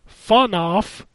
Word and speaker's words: FNAF: Bendy and FNAF, pronounced by the kids as Funoff, which is Five Nights at Freddy's, is all the rage with our son and all his friends.